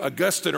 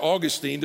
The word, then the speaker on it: Augustine: All right, it's like the 5th century saint, a guy named Augustine or Augustine, depending on how you want to pronounce it.